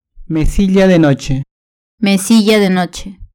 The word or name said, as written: mesilla de noche